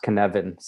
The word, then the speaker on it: Cynefin: It's pronounced Cynefin, C-Y-N-E-F-I-N, a Welsh word, a Cynefin, or Cynefin.